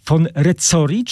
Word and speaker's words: von Rezzori: Ja nie miałem pewności, jak czyta się jego nazwisko, von Rezzori czy Rezzori? Von Rezzori, ponieważ tak, jest to włoskie nazwisko, włoskojęzyczne.